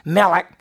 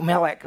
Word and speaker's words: Melech: Melech. It's pronounced like Melech. It has a guttural and Zedek.